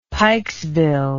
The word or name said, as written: pikesville